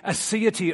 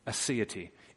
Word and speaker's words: aseity: This speaks not just to the immensity of God, that God cannot be measured, but to the aseity of God. That's something we don't often hear about. Let me spell it for you. Aseity. A-S-E-I-T-Y.